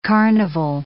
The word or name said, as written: carnival